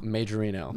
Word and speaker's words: Majorino: Majorino.